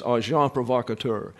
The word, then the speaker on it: agent provocateur: I like the term because it is a term that's pronounced this way. There's no plurals here with the S. It's agent provocateur.